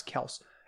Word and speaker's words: Kelce: Or Travis Kelce, I guess, is we've been saying it wrong. Travis, I'm sorry. Travis Kelce.